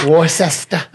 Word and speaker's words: Worcester: because they go and try to pronounce it. – Worcester. – Worcester. – Worcester. Worcester. – Wash your sister. – What is this? – You must be new here.